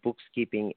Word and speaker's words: BooXkeeping: And, of course, BooXkeeping, it's spelled with an X instead of a K.